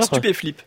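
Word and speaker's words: Stupeflip: Stup Flip, un trio de producteurs et de rappeurs, je ne sais pas si on prononce comme ça d'ailleurs. – Stup et Flip.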